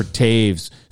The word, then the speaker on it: Toews: And but it's, you know, it's spelled Toews. Did you see that commercial when it was like election time, you know, like years ago, and it was like who wants to vote for Jonathan Toews or Taves spelled like toes, and they showed some hairy toes.